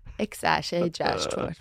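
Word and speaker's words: X Æ A-12: Nu ska jag säga, it means it's just X, the letter X, and then the AE is pronounced Ash, and then A12 is my contribution.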